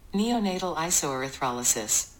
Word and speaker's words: neonatal isoerythrolysis: Neonatal isoerythrolysis.